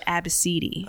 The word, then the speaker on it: ABCDE: Her daughter's name was spelled ABCDE. – Wow, goddamn! – And she pronounced it as Abbasidi.